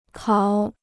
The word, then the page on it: 考: kǎo